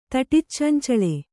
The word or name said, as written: ತಟಿಚ್ಚಂಚಳೆ